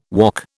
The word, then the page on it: wok: British English